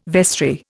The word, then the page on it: vestry: Indian